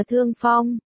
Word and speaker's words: thương phong: Sang phân đọc là sang phân, Hán Việt là thương phong, vừa có nghĩa là đồi phong trong đồi phong bại tục, vừa có nghĩa là cảm mạo.